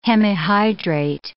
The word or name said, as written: hemihydrate